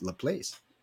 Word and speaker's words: Laplace: Well, I won like it was a $2,000 for somebody over Laplace because it's spelled L-A-P-L-A-E-C-E. It's Laplace. That's what it spelled like. I said, people, but it's French. French is Laplace.